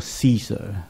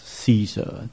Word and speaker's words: Caesar: 我们中文和本以前把它翻译作该撒，其实英文读作 Caesar， Julius Caesar，这是凯撒大帝的名字，对吧？